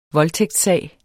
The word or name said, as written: voldtægtssag